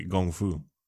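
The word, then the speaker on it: Gong Fu (功夫): In fact, I'm not even going to call it Kung Fu anymore. I'm going to say it correctly. Gong Fu.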